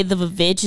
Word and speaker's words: VVitch: Reason why we say The Witch is because it's on every poster, it's spelled V-V-I-T-C-H. – Yeah. – And reason being is it's like old English that's had the right. A W is just 2 V's that they just like welded together.